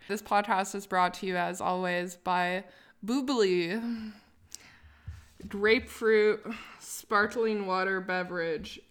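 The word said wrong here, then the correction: Bubly: It's pronounced bubbly. – Well, then why did they spell it like that? – I don't know.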